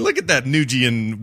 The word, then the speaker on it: Nguyen: I thought it's pronounced Nguyen. – It is Nguyen.